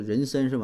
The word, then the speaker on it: 妊娠: 啊，是这个呃人参纹哈，啊就是妊娠这俩字一般读妊娠哈，就标准读音是人参是吧？